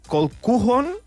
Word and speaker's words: Colquhoun: Alerta amb el nom, perquè és un cognom escocès bastant trambòlic, és Matt Colquhoun, si no vaig malament, se pronuncia així,